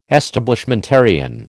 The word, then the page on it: Establishmentarian: American English